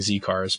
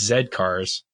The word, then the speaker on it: Z Cars: We're told, we've been pronouncing it wrong for so long, Z cars, but it's Zed cars.